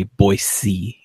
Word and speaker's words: Boise: But yeah, I believe most people living in the area say Boise. Yeah. – It's interesting that you picked that up because I was going to ask about that too, because I incorrectly always pronounced it Boise.